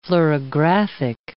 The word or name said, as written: fluorographic